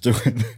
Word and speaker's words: Doohan: And then don't think Han, do Han. – Yeah, I think I mispronounced. Don't think Han, do Han, because his name's Doan. Right? James Doohan.